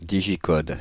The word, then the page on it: digicode: Île-de-France; /di.ʒi.kɔd/